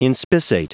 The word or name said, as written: inspissate